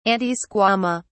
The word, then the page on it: antisquama: British English